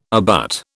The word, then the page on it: abut: British English